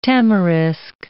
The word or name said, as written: tamarisk